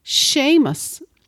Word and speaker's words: Seamus: However, the Irish language has a problem pronouncing the J sound in James, and so it changed it to a sh sound. As a result, James became Seamus, spelt S-E father, that's a long sounding on the E, A-M-U-S in Irish Scots Gaelic.